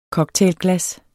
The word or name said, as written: cocktailglas